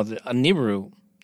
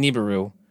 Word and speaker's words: Nibiru: Well, I mean, we're basically saying it the same way, except I'm putting the emphasis on a different syllable. I'm saying Nibiru, you're saying Nibiru.